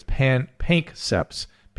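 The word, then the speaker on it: Panksepp's: I mispronounced the name. It's Pankseps. PANKSEPP. Apostrophe S.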